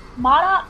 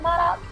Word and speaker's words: MODOK: The characters of, what was it, Modok? I can't pronounce his name. Is it Modok? M-O-D-O-K? – I think is it Modok or... Modok. Gary probably knows, because I know he's been reading those comics.